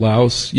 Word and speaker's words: Laos: Laos, I don't know how to pronounce it, Laos? Yeah, Laos. They had moved them to Laos.